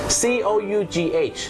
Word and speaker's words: cough: คุณผู้ชมครับภาษาอังกฤษเป็นภาษาที่บางครั้งเนี่ยนะครับการออกเสียงไม่แมทกับการสะกดอย่างแรงนะครับเช่นคำว่าอาการไอหรือมีการอาการไอนั่นเองครับ C O U G H ออกเสียงว่า cough cough